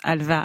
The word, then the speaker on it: halva: En Turquie, en Arménie ou en Israël, où on prononce halva avec un V.